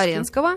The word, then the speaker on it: Флоренского: И вот всегда с детства я видела как раз моего с вами правильно ударение ставим Флоренского. Флоренского.